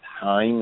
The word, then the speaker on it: Haim: I want to say her name is pronounced Haim.